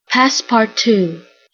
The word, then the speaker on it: Passepartout: Passepartout, French, passepartout.